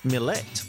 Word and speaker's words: Milat: But Milat's brother Bill says we've been pronouncing the name wrong all this time. According to Bill, who spoke to the Sunday Telegraph, the Yugoslavian name should be pronounced Milet.